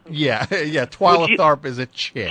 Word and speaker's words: Twyla Tharp: Twilight Tharp? Only the biggest choreographer on Earth, dummy. – Uh-huh. Yeah. Twyla? – What's that? – Twyla Tharp. – What is that? – That's the choreographer's name. – Spell it. – T-W-Y-L-A.